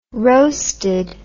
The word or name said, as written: roasted